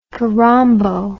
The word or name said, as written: kirombo